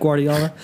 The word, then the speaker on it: Guardiola: They look like, you know, Pep Guardiola.